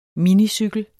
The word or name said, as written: minicykel